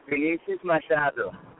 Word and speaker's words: Vinicius Machado: It's Vinicius Machado.